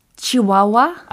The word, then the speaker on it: Chihuahua: Chihuahua.